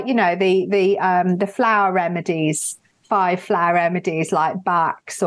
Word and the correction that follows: Bach: or Bach, yeah. – I believe it's actually pronounced.